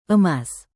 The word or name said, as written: emas